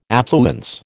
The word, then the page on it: affluence: American English